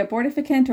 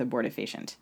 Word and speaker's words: abortifacient: Uh, Abortifacient? Am I saying it right? – Um, I've heard people say abortificant or abortifacient. – Okay, I guess I'm going to go with abortifacient.